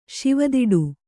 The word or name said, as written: ಶಿವದಿಡು